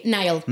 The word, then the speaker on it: Niall: Entrevistaste a Neil Horan. ¿Cómo se dice? Niall. – Sí, Niall. Niall, no Niall, esto se repite.